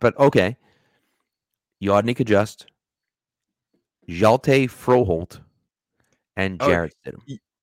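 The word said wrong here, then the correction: Hjalte Frøholdt: Yelda Froholt. I had to learn that pronunciation. – Yelda? – Yelda Froholt.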